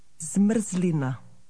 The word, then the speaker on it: zmrzlina: Zmrzlina.